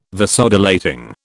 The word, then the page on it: Vasodilating: British English